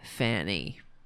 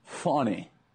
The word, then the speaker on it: Fani: Like immature children, Republicans pledge to intention- intentionally mispronounce Fannie Willis as Fanny. And here is Charlie Kirk. – Impressive piece of work by Fannie Willis. Oh, I'm sorry. It's Fani Willis. Fani.